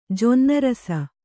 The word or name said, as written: ಜೊನ್ನರಸ